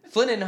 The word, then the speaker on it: Flininho: Flininho, Ho, Flying Ho. Flying Ho. It's Flininho. – It's Flanino, Flaninho, like Ronaldinho.